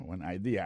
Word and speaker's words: idea: T-H-A, T-S, good, G-O-O-D pour good, ça veut dire bon, bonne, idea, c'est I-D-E-A, ça veut dire idée, that's a good idea. Alors, accent vient tomber dans la deuxième syllabe, là, one idea. Alors, moi, c'est idée, vous faites trois syllabes vraiment, I-D-A.